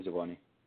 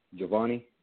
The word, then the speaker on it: Jhovanny: My name is Giovanni. – Say your name spelled, please. – J-H-O-V-A-N-N-Y. Giovanni.